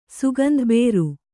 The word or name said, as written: ಸುಗಂಧಬೇರು